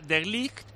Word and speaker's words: De Ligt: Y De Ligt se pronuncia De Ligt y Van Dijk se pronuncia Van Dijk.